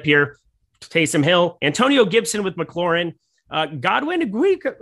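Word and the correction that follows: Igwebuike: how do you say his name again? – Igwebuke. – That was beautiful. Okay, maybe that was right.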